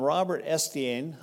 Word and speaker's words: Robert Estienne: Now, there was another fellow named Robert Estienne, I believe is how you say it.